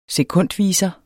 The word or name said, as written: sekundviser